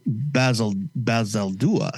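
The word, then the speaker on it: Bazaldua: again by writer Alex Packnadel and Marvel Stormbreaker Jan Basaluda. No, I'm sorry. Basaldúa.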